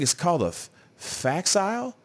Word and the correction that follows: facsimile: or something like that. – A facsimile? – A facsimile? I don't know what it is. You know, wrong accent on the wrong syllable, something like that.